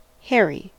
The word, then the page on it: hairy: American English